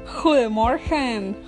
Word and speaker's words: Goedemorgen: Y qué mejor palabra para empezar este día que Goedemorgen, Goedemorgen. Bueno, eso significa simplemente buenos días en holandés. Lo importante es que aprendan a pronunciar la G, así, como con la garganta.